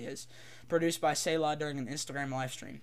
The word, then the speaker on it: Selah: I don't know how to say it. I think it's Say. How do you say that? Say. Huh? Selah. That's how you say it.